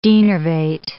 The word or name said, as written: denervate